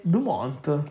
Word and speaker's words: Dumont: Dumont. Sembra Dumont. – Dumont. – Perché se non mi ricordo male la T e la S non vanno pronunciati in francese.